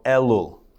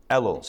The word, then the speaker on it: Elul: And the name of the month that we're in right now is called Elul. Elul. So in English, you would spell it E-L-U-L.